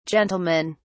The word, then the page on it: gentlemen: British English